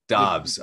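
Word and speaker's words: Doubs: Speaking of day 3, this day 3 run starts with Romeo Dubs. – Dobbs. Apparently it's Dobbs.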